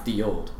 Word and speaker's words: Ye olde: Ye olde. It's pronounced the old.